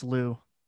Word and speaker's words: Liu: But Simu Liu, how do you pronounce his name? – It's Liu. Simu Liu.